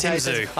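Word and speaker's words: Tim Tszyu: What's Tim Zhu? – Tim Zhu.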